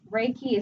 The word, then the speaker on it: Reiki: And Reiki is broken up to two syllables.